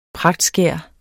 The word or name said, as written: pragtskær